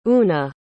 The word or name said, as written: una